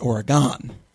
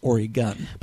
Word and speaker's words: Oregon: Well, last week I mispronounced Oregon and pronounced it Oregon. Being from the Midwest, that's how we were taught. So, but I was happy to be corrected by someone who is out there who could tell me the proper pronunciation, which is Oregon.